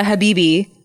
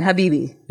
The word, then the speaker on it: Habibi: What I always say is, Roya Habibi, like BB guns, right? – Oh, is that your thing?